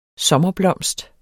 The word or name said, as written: sommerblomst